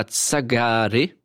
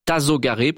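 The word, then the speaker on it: Tasogare: Tsagare. Je pense que ça se prononce pas comme ça. Tazogare plutôt.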